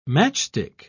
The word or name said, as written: matchstick